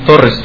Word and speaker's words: Torres: My name is Jesus Torres from Owatonna, Minnesota. – You want to spell that? – Jesus, spell like Jesus. My friends call me Jesus too. – What was your last name? – Torres, T-O-R-R-E-S.